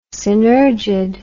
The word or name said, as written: synergid